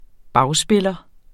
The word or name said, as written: bagspiller